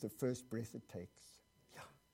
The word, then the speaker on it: Yahweh: But this is the way you say it. It's it's unpronounceable. You have to breathe it to say it. And it sounds like this. Yahweh. Did you hear that? Yahweh.